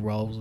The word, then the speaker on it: Rolls: We missed him out there with his leadership and experience in the back line, with our Golic having to cover Rouse a lot, or Rolls, I should say, because that's how you say it.